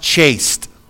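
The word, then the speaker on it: chaste (חסד): The Hebrew word for the word steadfast is pronounced chaste. Chaste.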